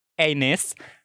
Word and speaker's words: Ennis: Oke, okay, pronunciation-nya benar itu Ennis. Agak-agak kayak kalau orang berbahasa Inggris ngomong anus kalau gue denger-denger sih. Karena agak nggak nyaman, gue pronounce nama dia Enis aja ya.